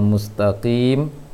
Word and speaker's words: mustaqim: Ihdinas siratal mustaqim tadi seakan-akan terdengar nun di ujungnya, seharusnya mim di ujungnya.